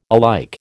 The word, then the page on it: alike: American English